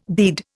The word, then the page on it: bead: Indian